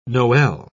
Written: noel

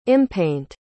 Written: impaint

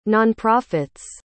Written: nonprofits

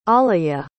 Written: alayah

